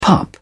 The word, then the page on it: pup: British English